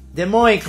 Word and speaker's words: Des Moines: Oye, y, sí, y esta parte en la que llegas a estacionarte, ¿por qué de Monoay? ¿Por qué? ¿Cómo se pronuncia? – Des Moines, como francés, ¿verdad?